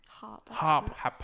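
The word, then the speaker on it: Happ: Developer Tom Hap? Is it Hap? – Hop. – Hop. – You have to pronounce both P's.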